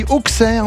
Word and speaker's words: Auxerre: La J Auxerre, vous avez dit Auxerre, vous, c'est rigolo. – Oui, en allant vite, je sais, vous savez, dès que ça concerne le foot, moi je prononce mal quelque chose.